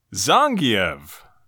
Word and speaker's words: Zangief: All right, this is where we pronounce his name correctly. Zangief.